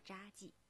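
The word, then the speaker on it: 查济: 在黄山山脉北部的群山之中，深藏着一个令人称奇的村落，它的名字叫做扎记。扎其实就是茶字典的茶字，但是在这里呢，多音字要念成扎，因为这里的大部分人都姓扎。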